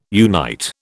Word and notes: unite: British English

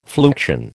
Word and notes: fluxion: American English

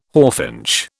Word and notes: hawfinch: British English

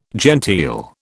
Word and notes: genteel: British English

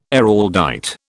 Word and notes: Araldite: British English

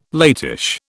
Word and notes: latish: British English

